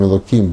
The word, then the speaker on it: Элоким: Не только слово, которое вы спросили, с которого начинается Тора, Берешит, Бара Элоким, это Шамаем, это Арец, мы произносим Элоким без буквы Гей, просто так, когда мы говорим, но в молитве и в изучении Торы, мы, когда читаем всю фразу Торы, Танаха, мы произносим правильно, полностью.